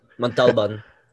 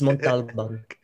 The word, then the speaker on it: Montalban: Montalban. – Montalban. Yeah, there you go. Our native. – It's my tito Paolo. I know how to say his last name. It's Montalban.